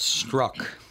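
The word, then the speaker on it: Strzok: Apparently it's pronounced Struck. – Oh, it's pronounced Struck? – Somehow. – It's not Jizok or whatever I said.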